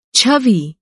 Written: छवि

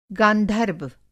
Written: गांधर्व

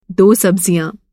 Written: सब्ज़ी

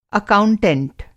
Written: अकाउंटेंट

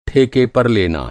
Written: ठेका

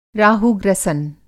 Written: राहु-ग्रसन

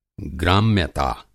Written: ग्राम्यता

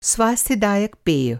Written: स्वास्थ्य-दायक

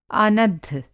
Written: आनद्ध

